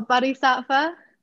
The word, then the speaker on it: Bodhisattva: And that brings me back to, I don't know how to pronounce it exactly, but the Bodhisattva. – Oh, Bodhisattva.